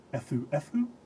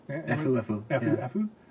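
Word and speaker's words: Efu-Efu (エフエフ): According to him, the original idea was to give the name a title that could be abbreviated to FF in English, in Japanese, pronounced Efu-Efu? – Efu-Efu. – Efu-Efu. Because it's a nice-sounding abbreviation to Japanese ears.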